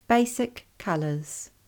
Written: Basic colours

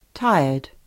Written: hiamoe

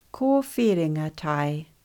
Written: Ko whiringa tae

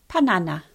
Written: Panana

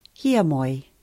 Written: hiamoe

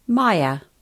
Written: maia

